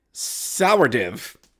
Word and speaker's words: Sourdif: It was uh, Justin Sourdif, uh, spelled like, pronounced like it's spelled S O U R D I F.